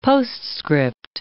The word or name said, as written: postscript